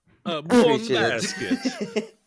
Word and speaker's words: Buon basket: Bene basketball. – Buon basket. That's how you say it. It's actually literally buon basket.